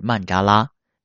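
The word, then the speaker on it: 曼 扎 拉 (मण्डल): 坛城的梵文发音就是曼扎拉，也即我们平时说的曼茶罗，表示中间有核心，周边是圆圈的世界。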